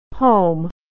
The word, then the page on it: home: American English